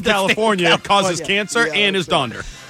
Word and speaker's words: Donder: It's Dasher, Dancer, and Donder or Donner. It's both, but Donder is the original Dutch spelling meaning thunder, while Donner became popular through the Gene Autry song and is the common spelling now, though some sources like California stick with Donder. – You know what? Donder rhymes with yonder, which is over where you can find someone who gives a flip.